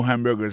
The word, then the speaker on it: hamburger: On a remarqué que dans le mot hamburger, ça a comme d'habitude, il y a trois syllabes là-dedans. Quand on appuie et puis plus ça, c'est sous premier syllabe là. I make hamburgers all day.